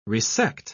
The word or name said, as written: resect